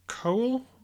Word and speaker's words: Cohl: And it ended up being a concert promoter called Michael Cohn. I can't really say it properly. COHL. Anyway. – Cohen, I'd say.